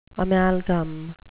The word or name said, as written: amalgam